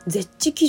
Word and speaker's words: ZEH: でこれあの私今まで読み方わかんなくて ZEH ってあのローマ字で書いてあのゼッチ基準というらしいです。ゼッチ水準。